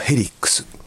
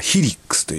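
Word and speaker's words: Helix: POD XT ライブというね、機材なんですけども、それをもうここ 10年ずっと使ってるんですけども、それの一番新しい、最近出ました、ヘリックス。正しくはヒリックスという発音らしいんですけども、なんかヒリックスってなんかちょっとね、なんか嫌なんでヘリックスと言わせていただきますけどもね。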